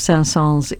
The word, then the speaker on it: Saint-Saëns: That's The Romance by Camille Saint-Saëns, and that was Richard Stoltzman playing the clarinet with harpist Nancy Allen. Yeah, that S at the end of Saint-Saëns is pronounced.